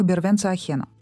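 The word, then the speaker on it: vergüenza ajena: И в оригинале на испанском это выражение произносится как «бервенца ахена», что переводится как «стыд за другого».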